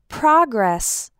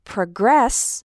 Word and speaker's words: progress: But the word progress, with the stress on the second syllable, is a verb.